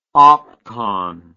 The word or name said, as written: opcon